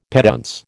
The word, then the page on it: petuntse: American English